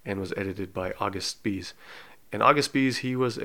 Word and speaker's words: Spies: So his last name is spelled like Spies, S-P-I-E-S. But I do know that in German language, if there's an I-E like that, it's pronounced, so his name would be pronounced Spies.